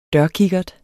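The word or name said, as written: dørkikkert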